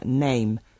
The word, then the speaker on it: name: Y hay palabras que se escriben con A, pero que se pronuncian con un sonido completamente diferente, como, por ejemplo, la palabra name, nombre, que se escribe N-A-M-E y se pronuncia con el diptongo A.